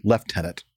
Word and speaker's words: Lieutenant: Nick will know that it's pronounced, Lieutenant. I even mispronounced it, even though I knew that I was not supposed to do that.